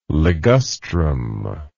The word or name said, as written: ligustrum